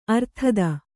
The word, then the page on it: ಅರ್ಥಿತ: arthita